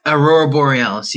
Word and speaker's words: Aurora Borealis: Have you seen the Aurora Borealis? Am I saying that right? – Aurora Borealis, yes.